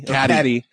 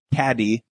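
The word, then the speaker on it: Cady: Katie. – Caddy. – Ah. – Depending on how you pronounce it. She has a D in her name. Caddy. But anyway, she moves in from South Africa.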